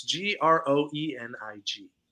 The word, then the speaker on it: Groening: It's Groening.